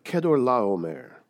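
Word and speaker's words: Chedorlaomer: And it's also known as the slaughter of Kedorlaomer, which is how you pronounce that name, which is really difficult to pronounce.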